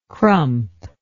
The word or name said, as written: crump